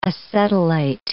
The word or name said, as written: acetylate